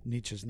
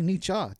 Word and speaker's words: Nietzsche: that most people mispronounce Nietzsche's name. They say Nietzsche, yeah. – And they get it right. They say Nietzsche.